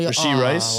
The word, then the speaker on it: Rashee Rice: I'm Rasheed? Not gonna say his name. So Rashee Rice.